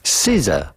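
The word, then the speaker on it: SZA: S-Z-A. S-Z-A, how do you go about pronouncing this? Well, it's pretty straightforward once you know. Caesar. Caesar. Caesar. Caesar. Pretty easy. Caesar.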